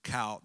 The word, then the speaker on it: Chaldeans: I will use what my Bible gives me as pronunciations, and that's why I call it Chal. That CH is underlined as a long K sound.